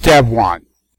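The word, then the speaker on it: Devuan: It's spelled Devuwan, but I understand the canonical pronunciation. It's Devwan.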